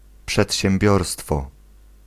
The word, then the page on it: przedsiębiorstwo: [pʂɛtɕɛmˈbʲɔrstfɔ]